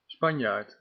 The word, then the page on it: Spanjaard: /ˈspɑnj.aːrt/